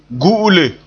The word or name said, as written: gu'ule